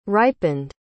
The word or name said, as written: ripened